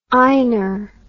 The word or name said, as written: einar